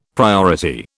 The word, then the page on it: priority: British English